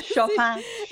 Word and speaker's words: Chopin: was it, Chopin? How do you say that, yes. – Exactly. Chopin, no doubt.